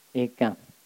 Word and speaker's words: mekam: Asankarikam mekam. – Asankarikam mekam tức là nối, đọc nối hai từ chữ M qua chữ E, đọc là mekam.